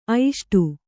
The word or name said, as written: ಐಷ್ಟು